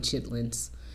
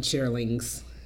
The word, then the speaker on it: chitterlings: Okay, people don't say it that way. They call them chitlins, but I call them chitterlings. What are they? – You just said three different things. – Chitterlings, chitlins. That's how they say it.